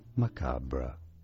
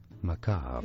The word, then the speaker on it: macabre: This word in British English, macabre. In American English, macabre.